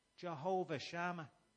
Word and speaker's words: Jehovah Shammah: Jehovah Shammah.